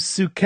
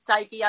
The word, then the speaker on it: Psyche: So today we're going to talk about Ode to Suke. Now, that's how I pronounce it. You pronounce it how? – I pronounce it psyche, as in from psychology. Yeah.